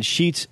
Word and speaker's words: Schetz: There's a couple names, and I can understand because my last name being Sheets, S-C-H-E-T-Z, people think it's spelled like the Sheets Market. – Yeah. – Right. – Sometimes they drop the C and the Z and just add an S, so like it's a bed sheet.